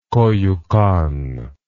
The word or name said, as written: koyukon